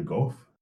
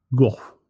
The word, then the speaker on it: Gogh: On the 29th of July, 1890, Vincent van Gogh, or how do you say it in Dutch? – It's Gogh. – Yeah, something more like Gogh. – Not go, as some people say.